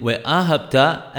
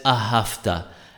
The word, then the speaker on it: Ve'ahabta: For instance, those who pronounce the letter B without a daresh as a V must be particular not to pronounce it in Ve'ahabta as Ahafta, and instead of Nafshecha, Navshecha.